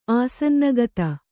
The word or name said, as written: ಆಸನ್ನಗತ